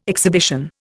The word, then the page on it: exhibition: Indian